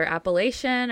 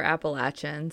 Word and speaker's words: Appalachian: It depends on where you're from. It's either Appalachian or Appalachian.